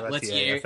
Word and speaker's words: Leterrier: He's French, so Louis Leterrier. – Louis Leterrier. – Yeah, Leterrier. Okay, sure.